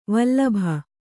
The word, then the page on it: ವಲ್ಲಭೆ: vallabhe